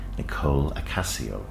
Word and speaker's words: Nicole Acacio: So I'm very pleased to welcome Nicole Acacio. Did I get your name right? – You did.